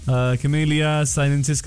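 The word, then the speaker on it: Camellia sinensis: लिजी ने भी मैसेज किया कमिलिया सिनेंसिस करके। कमरिनिसा जी ने मैसेज किया, उन्होंने भी सही जवाब दिया है और हिंदी में भी प्रोनाउंसिएशन दिया है कैमेलिया साइनेंसिस करके। वाह पता है कि उनकी जो बच्ची है वो नहीं बोल पाएगी।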